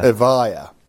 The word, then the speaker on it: Evija: Evija.